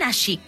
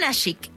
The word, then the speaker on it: Nashik: Nació en Nashik. ¿Lo digo bien? – Sí, nací. – Nació en Nashik, en la India.